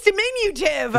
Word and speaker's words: diminutive: It actually is a real word. Diminutive. Diminutive. Oh, it's diminutive. – Is that it? – I added an extra syllable.